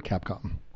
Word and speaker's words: Capcom: What is the proper pronunciation of the word Capcom? – You just said it. – Really? – Yeah. – I feel like there's a little bit more flourish and fanciness. – There is not.